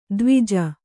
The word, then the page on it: ಧ್ವಜ: Kannada; dhvaja